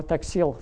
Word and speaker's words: Taxil: His name was Leo Taxil, or Taxil, French has stress on the last syllable.